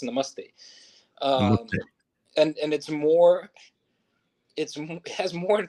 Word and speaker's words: namaste: Yeah, it's namaste, which is like hummus, like namaste. Mm. And then the T is flat.